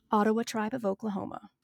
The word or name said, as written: Ottawa